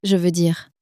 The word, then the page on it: je veux dire: french